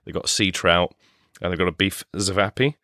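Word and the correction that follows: ćevapi: which is chivapi.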